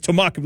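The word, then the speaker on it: Te'mexw: Tamuk is a word that means land. It's in Hulquitim, and it means land in English. And it's spelled out T-E-apostrophe-M-E-X-W.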